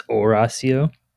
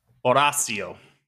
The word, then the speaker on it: Horacio: But anyways, the H is silent, allegedly. – It's Horacio. – Horacio.